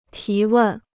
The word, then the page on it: 提问: tí wèn